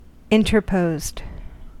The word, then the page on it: interposed: American English